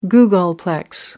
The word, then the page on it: googolplex: American English